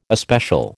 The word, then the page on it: especial: American English